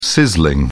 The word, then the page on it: sizzling: British English